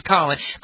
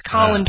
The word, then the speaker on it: Colin: It's always colon. Colin Powell even said he had never been called colon until the press butchered his name. He's Colin Powell.